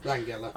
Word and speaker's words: Langella: Langella. – I say Langella because I don't want him to sound like Bongella.